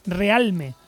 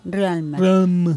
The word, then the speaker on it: Realme: ¿Se llama RAM? – Realme. Se escribe Realme. – Vale. – Es Realm. – Realme. Bueno, pues ya lo sabéis.